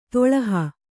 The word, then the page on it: ದುಲ್ಲಹ: Kannada